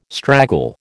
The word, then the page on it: straggle: American English